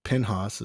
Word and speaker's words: Pinhas: Hophni in Hebrew, and Phinehas in English, and Pinhas is how they say it in Hebrew as well. When I lived in Israel, I knew a lot of people named Penny, and it was just short for Pinhas.